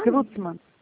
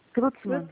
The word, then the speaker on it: Klutzmann: É Kutzmann que fala? – Reni Klitzmann. É Reni. – Reni. Como é que fala o sobrenome? – Klutzmann. Klutzmann.